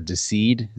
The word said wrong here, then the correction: Deicide: Is that how you pronounce that band? – Oh, Deicide. – Deicide. Deceed. Oh, look at me, Mr. When I first saw it, yeah, I was like, it's Deicide, what? – Deicide.